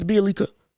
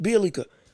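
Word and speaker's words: Bjelica: I believe I'm saying his name right. Excuse me if I'm not. Apologize if I'm not. They had Bielita, the white guy. So again, I apologize. I can't say his name right. So sorry. I believe it's Bielica. Bielica, that's it.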